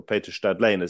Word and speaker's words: Stadlen: Your father, Edmund Wolfe, I knew him as Mundy, was best friends with my grandfather, Peter Stadlen, or Peter Stadlen, as his name would have been pronounced in Austria, where they were both from.